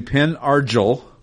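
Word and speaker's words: Pen Argyl: I apparently had the emphasis on the wrong syllable. So, and what town was that? – That would be Penn Argyle.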